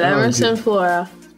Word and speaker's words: Emyrson Flora: Emyrson Flora.